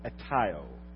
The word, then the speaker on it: aiteo: The word ask in the Greek is a little word called aiteo. Aiteo, A-I-T-E-O in the English language. If we what we call transliterated. That means we write in Greek how to pronounce it in English. So, aiteo.